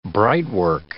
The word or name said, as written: brightwork